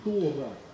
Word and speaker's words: Тора: Вообще, друзья, Пятикнижие Моисея, или по-еврейски Тора, или Тара, и так можно произносить, это правильное произношение, так и так, это отдельно стоящая книга во всем Ветхом Завете.